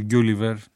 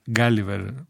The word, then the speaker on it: Γκάλιβερ: Γκιούλιβερ, Γκάλιβερ προφέρεται έτσι αν θέλουμε να είμαστε ορθοί,